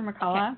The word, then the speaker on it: McCulloch: Dr. McCulloch. Okay. – McCulloch. – It's actually Scottish, so you're supposed to say Dr. McCulloch, but nobody says that.